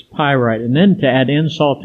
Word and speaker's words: pyrite: He took his magnifying glass and looked it over and promptly pronounced pyrite.